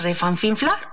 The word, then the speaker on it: refanfinflar: Porque refanfinflar, por favor, a ver, ¿es refanfinflar o repampinflar? Si bien refanfinflar como repampinflar, es decir, tanto refanfinflar como repampinflar son válidos.